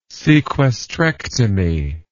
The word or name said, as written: sequestrectomy